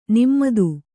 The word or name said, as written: ನಿಮ್ಮತು